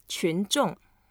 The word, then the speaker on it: 群众: 群众. – 群众. – 群众. And it's so heavy.